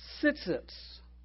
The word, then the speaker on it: tsiytsiyts: It's a real strange word. I get a kick out of it. Tsitsits. I'm going to spell it. T-S-I-Y-T-S-I-Y-T-S. It's almost as strange as Mississippi.